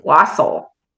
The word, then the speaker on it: wassail: Wassail. Have a wassail. That's the stuff. – It's wassail? like oh, it's it's spelled like wassail, but it's yeah. pronounced wassail. It's a Christmas time drink, and it's like oh.